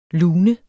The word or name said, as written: LUNERNES